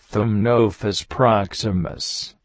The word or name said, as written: Soles